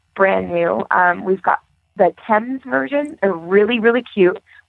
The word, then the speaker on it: Themes: It's pronounced Thames, the Dublin Thames boot, but it's spelled T-H-E-M-E-S.